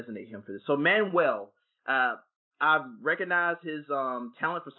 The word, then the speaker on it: Manuel: So how do you pronounce his name? Lin-Manuel Miranda? – Manuel. Close. So yeah, good enough.